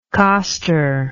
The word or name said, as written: coster